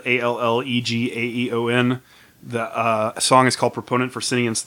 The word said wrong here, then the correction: sentience: That was sentience. – Whatever. – You said it weird.